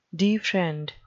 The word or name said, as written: defriend